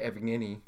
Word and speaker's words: Evgeny: How do you say the first? – I say Evgeny. – Evgeny? – Yeah. Or Douche Nugget. – Evgeny.